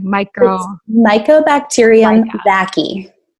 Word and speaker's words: Mycobacterium vaccae: Say that word again. I'm sorry. – Mycobacterium vaccae. – Mycobacterium vaccae. Yeah. I didn't say that right. Vaccae.